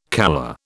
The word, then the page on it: calla: British English